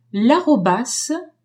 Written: arobas

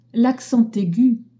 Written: aigu

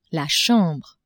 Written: chambre

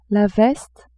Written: veste

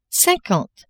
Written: cinquante

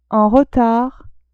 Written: en retard

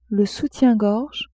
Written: soutien-gorge

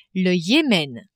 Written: Yemen

